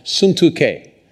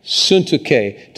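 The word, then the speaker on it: Syntyche: Now listen to this name, this next name. It's pronounced Suntuke. And Suntuke, to be of the same mind in the Lord.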